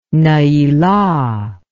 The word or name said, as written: neilah